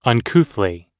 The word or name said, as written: uncouthly